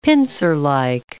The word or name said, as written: pincerlike